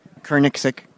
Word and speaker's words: Koenigsegg: How do you say this? – Koenigsegg. – Koenigsegg. – Koenigsegg. – Koenigsegg.